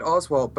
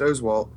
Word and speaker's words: Oswalt: They call it Oswalt, but it's pronounced Oswalt.